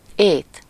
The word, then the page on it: food: /fuːd/